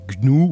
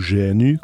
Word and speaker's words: GNU: Dans l'épisode, à un moment, je fais le distinguo entre Linux et GNU, ou GNU, comme vous voulez, au niveau de la prononciation.